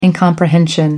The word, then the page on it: incomprehension: American English